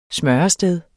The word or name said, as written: smørested